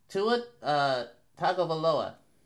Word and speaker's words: Tua Tagovailoa: I want to hear you pronounce his name since you're so into it. – Tua Tagovailoa. – All right. I give him credit. He did pronounce it right.